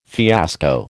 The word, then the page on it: fiasco: American English